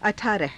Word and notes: अठारह: a thaaraha

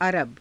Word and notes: araba: Hindi

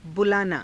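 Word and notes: बुलाना: bulaanaa

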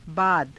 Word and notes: baada: Hindi

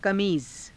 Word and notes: kamiiza: Hindi

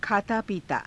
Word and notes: khaataa piitaa: Hindi

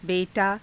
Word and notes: be_taa: hindi

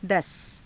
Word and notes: dasa: hindi

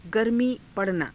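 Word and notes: गर्मी पड़ना: garmii pa ranaa

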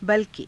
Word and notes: balki: hindi

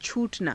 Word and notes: chuu_tanaa: hindi

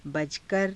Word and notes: bajakara: hindi

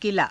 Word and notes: kilaa: Hindi

